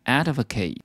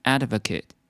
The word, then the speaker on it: advocate: 支持或拥护这个动词呢，我们可以念 advocate，而它当名词的时候呢，就可以当支持者或拥护者。但是我们的念法呢，要念成 advocate。